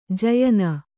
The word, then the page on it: ಜಯನ: jayana